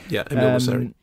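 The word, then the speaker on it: Emile Mosseri: Then News of the World, James Newton Howard, Minari, Emil Mosseri. Is that how you pronounce his name? – Yeah. Emil Mosseri.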